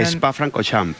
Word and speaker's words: Francorchamps: Spa Francorchamps. – Ah, vale, lo he dicho bien. – Esto, todo esto con acento francés, imagínate.